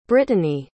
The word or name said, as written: britanny